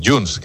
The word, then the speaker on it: Junes: Entonces el tipo, el secuestrador, Junes, que así es como se pronuncia en árabe, efectivamente pues será un individuo con un perfil pues un tanto llamativo, ¿no? – Peculiar, sí.